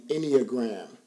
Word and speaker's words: Enneagram: This stuff is so good, and it's called the Enneagram. – Yeah. – Did I say that right? – You did.